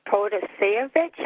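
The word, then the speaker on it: Protasiewicz: We need to get out and vote for Judge Protasevich. I hope I said that correctly. – Yep.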